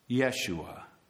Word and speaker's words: Yeshua: His name in Hebrew, the pronunciation of Jesus, is Yeshua, Joshua.